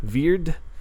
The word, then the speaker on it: Wyrd: I'm sure that's pronounced like a V, WYRD.